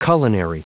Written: culinary

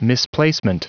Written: misplacement

